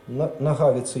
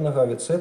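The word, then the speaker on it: Nohavica: Во-первых, потому что все-таки ударение в имени фамилии автора на второй слог Нагавица, Яромир Нагавица, это чешский бард.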